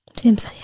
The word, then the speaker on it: SAMHSA: And then you have the, how do you say this, SAMHSA? – SAMHSA, yeah. – SAMHSA.